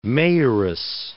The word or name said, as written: mayoress